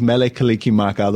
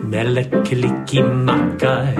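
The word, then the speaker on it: Mele Kalikimaka: The Hawaiian language has no sound for R or S, and every syllable has to end with a vowel sound. So Merry becomes Mele, and Christmas becomes Kalika Maka.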